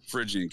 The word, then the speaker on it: Phrygian: Yeah, and the Statue of Liberty used to be depicted with that same, how'd you pronounce it, Phrygian cap? – Yeah. – Phrygian cap, there we go.